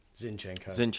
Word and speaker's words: Zinchenko: Is it Ozchenko? What's his name? – Zinchenko.